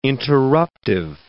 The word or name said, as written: interruptive